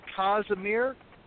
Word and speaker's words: Casimir: 1668, this looks like King John, I think you pronounce it Casimir V of Poland, abdicates the throne.